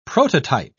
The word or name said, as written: prototype